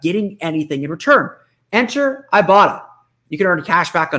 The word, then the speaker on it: Ibotta: But first, a quick word from our friends at Ibotta. See, I've been pronouncing it wrong the whole time, and there's a pronouncer right here on my copy that says Ibotta.